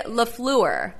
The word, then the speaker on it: LaFleur: He said it's supposed to be LaFleur. Like LaFleur.